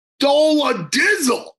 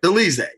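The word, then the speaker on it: Dolidze: Roman Dola Dizzle. No, I'm only kidding. How do you pronounce Rose's last name? – I believe it's Delize. – Delize. It had nothing to do with Dola Dizzle. Delize.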